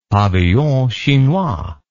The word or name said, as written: pavillon chinois